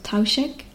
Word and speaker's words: Taoiseach: They have a president, which is currently Michael Higgins, and there is also a prime minister, which is known as the, I believe it's pronounced Taoiseach. It's an Irish word, so forgive me if anybody Irish is listening.